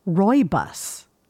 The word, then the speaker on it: Rooibos: Instead, Leia Rooibos is pronounced Rooibos. I did not know that.